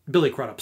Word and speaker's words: Billy Crudup: It's actually not that deeply, like, it has one major flaw, which rhymes with Silly Butt-Up. Billy Crudup sucks in that movie.